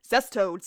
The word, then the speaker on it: cestodes: And yes, I had to look up how to pronounce that correctly. And I'm going to say it correctly the entire time. Cestoad, cestoad, cestoad, cestoad.